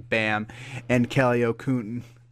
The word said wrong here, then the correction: Olynyk: or how do you say that? – Kelly Olynyk. – O-L-Y-N-K-Y. Kelly Olynyk? Is that what you're talking about? – There you go.